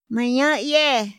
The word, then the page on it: ma ñayë: Otomí